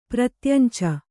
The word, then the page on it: ಪ್ರತ್ಯಂಚ: pratyanca